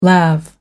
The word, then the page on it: lav: American English